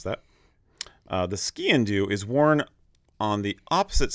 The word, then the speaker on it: sgian dubh: For those who do not know, skin-do It's spelled, it's a Gaelic word, S-G-I-A-N mm-hmm. space D-U-B-H. Mm-hmm. Pronounced skiing, do like skiing into something, skiing.